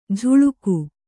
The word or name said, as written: ಝುಳುಕು